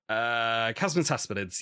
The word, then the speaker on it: chasmataspidid: Oh, I said it wrong first time I said it. I was like, chasmataspidid. And my supervisor was just like, chasmataspidids, yes, great.